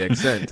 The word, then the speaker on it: The Ascent: Did you play the Accent? I've been heavy into Accent lately. – Did you say accent? – The ascent. The ascent. – Like to rise.